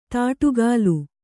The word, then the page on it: ದಾಟುಗಾಲು: Kannada